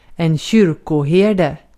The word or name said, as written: kyrkoherde